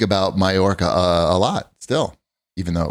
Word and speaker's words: Mallorca: You know, when I said Mallorca, when we were online for the ferry to get there, mm-hmm. and the woman behind me corrected my pronunciation.